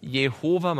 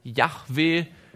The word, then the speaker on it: Yahweh: Einige haben geglaubt, das heißt Jehova. Man hat wahrscheinlich deutlich zeigen können, dass das höchstwahrscheinlich nicht die richtige Aussprache gewesen ist. Man hat erschlossen, dass es wahrscheinlich so etwas wie Yahweh gewesen sein wird.